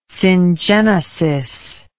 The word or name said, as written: syngenesis